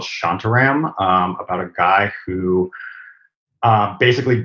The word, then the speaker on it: Shantaram: Shantaram. Shantaram. – Shantaram.